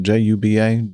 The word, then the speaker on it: Juba: Is that the way you pronounce that? J U B A, Juba? Yep, I would say so. Or Juba.